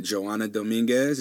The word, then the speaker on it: Johana Dominguez: So I am here with Joanna Dominguez.